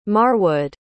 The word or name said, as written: marwood